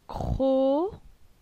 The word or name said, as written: Cro